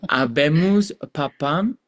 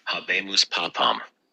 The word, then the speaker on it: Habemus papam: So I know everybody's heard the news by now, but Avemus Papam, I think that's how you say it. Habemus papam. Let me listen to a little video first. Pronunciation. – Habemus papam.